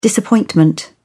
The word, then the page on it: disappointment: British English